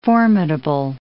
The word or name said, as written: formidable